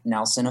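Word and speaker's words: Nelsen: I'm very active on my Instagram, which is at Eric Nelson Official. But Nelson is spelled weird. I always have to tell people it's N-E-L-S-E-N. Most Nelsons are spelled with an O, but mine's an E.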